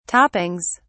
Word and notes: toppings: British English

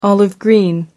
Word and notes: olive-green: American English

